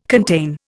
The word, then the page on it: contain: Indian